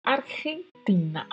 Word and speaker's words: Argentina: O G seguido do E é pronunciado com um som que vem da garganta. Argentina, Argentina.